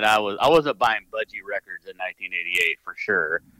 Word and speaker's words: Budgie: And Breadfan was originally by how do you say Boogie? Budgie. Budgie. I never heard of Budgie.